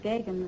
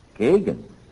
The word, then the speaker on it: Geoghegan: Pronounced Gagan, Mr. Godfrey. – Gagan? G-E-O-G-H-E-G-A-N comes out Gagan?